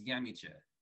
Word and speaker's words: Nyamekye: So, my middle name is Yamiche. Yamiche. Say it again? – Did you spell that? – Oh, yes. N-Y. A-M-E-K-Y-E.